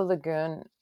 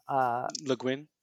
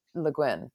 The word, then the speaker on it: Le Guin: And it was like this like weird sort of Ursula Le Guin. I'm saying her name wrong. – Le Guin. – Le Guin. Thank you.